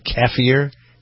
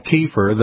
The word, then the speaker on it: kefir: I put that in there with, I'm using, I don't know how to pronounce it, kefir? – It's Americans call it kefir. The people kefir. That develop it called it kefir.